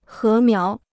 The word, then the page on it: 禾苗: hé miáo